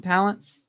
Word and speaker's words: Palance: I mean, Jack Palance. Is that how you pronounce his last name? Palance? – Palance, yeah. Jack Palance.